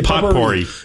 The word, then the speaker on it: potpourri: It's not spelled the way it sounds, but yes, potpourri.